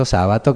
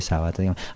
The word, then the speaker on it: Sabato: Ernesto Sábato que se escribe sin acento descubrí el otro día horrorizado me sigue pareciendo genial es una gran parte del libro que demuestra que, bueno, que Sabato podía escribir muy bien.